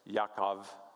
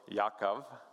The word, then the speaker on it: Yaakov: It was like Yaakov is kind of how you pronounce it after me looking it up online and listening to that multiple times. So Yaakov, we would then transliterate it.